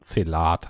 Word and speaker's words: Zelda: Zelda. Hab es fast durch. Das auch hier wieder falsch ausgesprochen. Zelat heißt das.